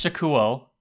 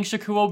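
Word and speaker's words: Shakkoumon: Sorry, is it not pronounced Shako? I thought it was Shako. – It's Shakuo. – Huh. – At least I remember it being Shakuo, because Shakumon is like that little clam with the little gooey thing inside.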